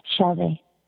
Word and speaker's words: Shelby: Shelby. – Can you spell that? – S-H-E-L-B-Y.